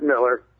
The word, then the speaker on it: Miller: I'm not sure if it's Miller or Mueller. People say it different ways when you spell it like that. Welcome to the show. – Thanks for having us. Yeah, I appreciate it. And for the record, it's Miller.